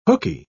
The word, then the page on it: hookey: British English